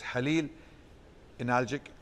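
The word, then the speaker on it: Halil İnalcık: His name was Halil Analjik, or Halil Analjik, but Kha is not pronounced in Turkish, so it's Halil Analjik, and he lived up to 100 years, from 1916 to 2016.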